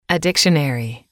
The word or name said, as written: a dictionary